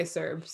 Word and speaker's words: CERB: I don't love the ending of the CERB or the CERB or I'm going to go with the hard C because it's Canada and that's how we say it. We don't say Sanada.